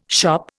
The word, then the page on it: shop: Indian